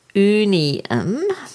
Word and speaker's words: Oonieemme: So I did a little bit of research and I found out that Archangel Oonieemme, it's spelled Oonieemme, and it's pronounced Oonieemme,